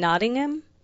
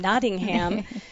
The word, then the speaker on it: Nottingham: And this is from Louise in Nottingham. Did I say that right? – Yes, you did. – That's Robin Hood. Is that Robin Hood? – Yes, it is. Sherwood Forest. Uh-huh. – Nottingham.